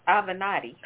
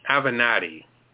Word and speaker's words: Avenatti: Avenatti. – Avenatti.